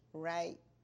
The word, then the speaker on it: Wright: Mattie Sue Wright.